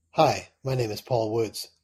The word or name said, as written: paul